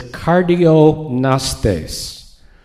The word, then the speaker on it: kardiognostes: The verb is kardiognostes. Cardio, compound Greek word. Gnostes with the silent G.